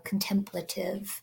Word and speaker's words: contemplative: So I think this is much more contemplative. That's how you say it, right? Contemplative. – Yeah.